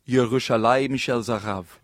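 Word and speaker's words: Yerushalayim Shel Zahav (ירושלים של זהב): I'm a little nervous, but I think it's pronounced Yerushalayim Shel Zahav.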